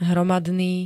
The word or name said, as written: hromadný